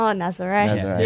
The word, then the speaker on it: Nazaré: Or Nazir. I don't know. How do you pronounce it? – Oh, Nazaré. Yeah.